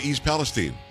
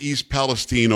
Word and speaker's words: East Palestine: Look, Carrie, it's not complicated that it's not pronounced Palestine. – Why is it people can't get that straight? I mean, there's the Palestine that is a country that doesn't exist and never did, where Palestinians are from, and we pronounce it Palestine.